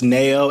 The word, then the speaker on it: Nao: I think her name is pronounced Nao, N-A-O.